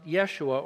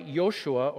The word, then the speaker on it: Yeshua: In Hebrew, it's pronounced Yeshua, or sometimes the variety Yoshua or Yehoshua when you want to emphasize the Lord is saving.